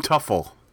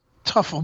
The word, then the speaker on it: Teufel: Tim Tuffel. – Tuffel, but it was spelled T-E-U-F-E-L. Is that how you spell it? – Yeah, T-E-U-F-E-L.